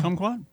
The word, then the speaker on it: kumquat: Kumquat? – Yeah. Yeah. Kumquat sounds, it's like the country of Guam.